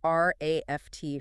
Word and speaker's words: RAFT: It's known as the RAFT framework. – And we should clarify, that's spelled RAFT, but pronounced exactly like the word raft.